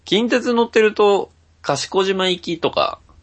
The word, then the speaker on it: 賢島: その、近鉄四日市で、賢島行きの、電車と、その賢島という漢字を見て、ああ、これは賢島って読むんだな、っていうのを知ってるぐらいなので。